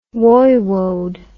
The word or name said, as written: woiwode